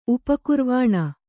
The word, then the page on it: ಉಪಕುರ್ವಾಣ: upa kurvāṇa